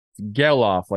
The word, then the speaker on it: Gelof: Gelof, like sell off.